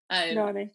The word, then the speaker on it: Nani: I'm gonna say this wrong, Nani. – Nani.